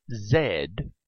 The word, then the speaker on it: zed: It is named Zed with a short e in England, Canada, Australia, and New Zealand. In America, the letter is Z with a long e.